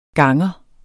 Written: ganger

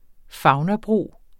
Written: faunabro

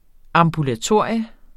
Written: AMBULATORIET

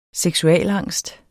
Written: seksualangst

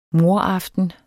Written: mordaften

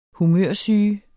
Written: humørsyge

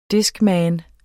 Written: discman

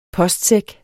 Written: postsæk

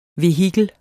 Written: vehikel

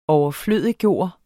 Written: overflødiggøre

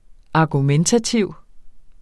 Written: argumentativ

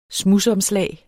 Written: SMUDSOMSLAG

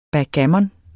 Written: backgammon

